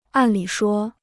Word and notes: 按理说: àn lǐ shuō

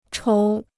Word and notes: 筹: chóu

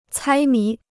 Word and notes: 猜谜: cāi mí

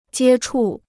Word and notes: 接触: jiē chù

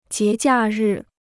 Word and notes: 节假日: jié jià rì

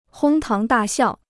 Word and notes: 哄堂大笑: hōng táng dà xiào

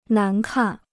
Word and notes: 难看: nán kàn

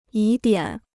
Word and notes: 疑点: yí diǎn

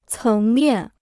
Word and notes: 层面: céng miàn

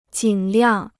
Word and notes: 尽量: jìn liàng

